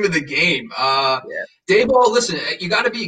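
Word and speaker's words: Daboll: Dable, yeah.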